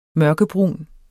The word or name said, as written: mørkebrun